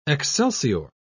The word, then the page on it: excelsior: British English